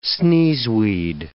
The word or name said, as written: sneezeweed